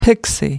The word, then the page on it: pixie: American English